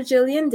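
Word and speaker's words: Gillian: So anyway, you can find me on Facebook as Dr. Jillian Davis, all lowercase, regular letters.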